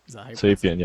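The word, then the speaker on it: Xapian: Zapian, yeah.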